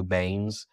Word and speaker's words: Baines: So if you go to LinkedIn and you type in Andrew Baines, my last name is spelled B as in boy, A-I-N-E-S.